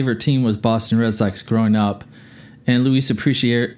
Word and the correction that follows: Aparicio: I can't even say his name. Lu, Luis Luis Aparicio.